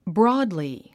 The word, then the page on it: broadly: brɔ’ːdli; ブロードリィ